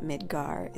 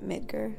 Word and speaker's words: Midgar: And I know they pronounce it Midgar in the game, but I grew up pronouncing it Midgar, so that's how it'll be.